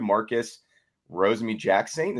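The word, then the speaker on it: Marcus Rosemy-Jackson: Marcus Rosemy Jackson. Is that how you say it? – Yes.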